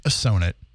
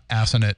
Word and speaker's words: Assonet: So the story goes, for those unfamiliar, the Assonet Ledge, and by the way, one more time, it's pronounced Assonet, not Assonet.